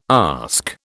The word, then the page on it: ask: British English